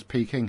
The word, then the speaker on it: Peking: It says Peking here. During no, but if you look at how, if you find a pronunciation guide for the Wade Giles transliteration of Chinese, P-E is Bay. K-I-N-J.